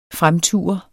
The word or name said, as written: FREMTUR